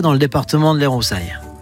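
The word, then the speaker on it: Cy: Donc, on peut que vous inviter donc à frapper à la porte et à aller voir Sai. Ça s'écrit C-Y, mais son vrai prénom, c'est Julien.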